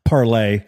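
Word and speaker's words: Parler: The other lane is, I do think you brought up Facebook, Twitter, and Parlay, I think is the way they pronounce it. It's a French word. – You said how? – Parlay.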